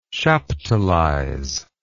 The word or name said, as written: chaptalize